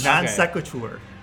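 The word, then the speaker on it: non sequitur: Non-sequitur. – I don't think that's pronounced that way. – Believe me, it is. It's Latin.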